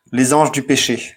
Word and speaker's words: Les Anges du Péché: Okay, so before the police gets to you, Chris, just to protect you, Les Anges du Péché is how that's pronounced.